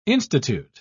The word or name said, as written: institute